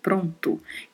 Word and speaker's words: pronto: A pronúncia dos dois os são bem fechadas, falamos pronto, não pronto, como falamos comumente no português em várias regiões do Brasil. A pronúncia correta é pronto.